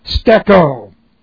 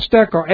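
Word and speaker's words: Steko (στήκω): Present, active, indicative of steko. Present, active, indicative of steko. S-T-E-K long O. Steko. It's a long E, by the way.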